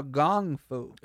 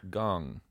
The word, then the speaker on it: Gong Fu: No, it, no, no, Gong Fu. – As they pronounce it, yes. – Is it Gon or Gong? – Gong. – Gong. Okay.